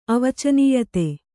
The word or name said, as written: ಅವಚನೀಯತೆ